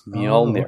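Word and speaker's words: Mjölnir: I thought it was Mjolnir. – The J is the second letter. It's Mjolnir. – Oh, okay. I'm just used to Mjolnir.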